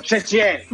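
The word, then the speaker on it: Xie xie (谢谢): Xie xie.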